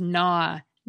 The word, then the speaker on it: gnaw: Yet another consonant cluster that English doesn't have anymore is GN, which is the source of the silent G in words such as gnaw, gnat, and gnarly.